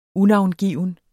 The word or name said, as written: unavngiven